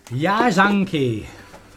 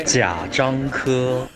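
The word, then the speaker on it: Jia Zhangke: Quindi per favore, ci dici come si pronuncia veramente? – Ya Zhangke. – Oppure Jia Zhang He. – Praticamente è uguale. – Praticamente si dice Zia Cianco.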